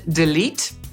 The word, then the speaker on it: delete: Podsumowując, angielskie słowo, które zapisujemy delete i znajdziecie je na swojej klawiaturze, wymawiamy delete, i oznacza kasować albo usuwać.